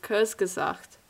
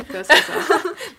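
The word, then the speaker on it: Kurzgesagt: It's Kurz, guys. – Kurz gesagt. – There you go. – Kurz gesagt.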